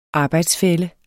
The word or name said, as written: arbejdsfælle